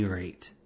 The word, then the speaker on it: Raitt: I will concede that her name is Bonnie Raitt, R-A-I-T-T, mm-hmm. and there's no, it's a single syllable.